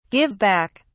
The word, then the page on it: give back: British English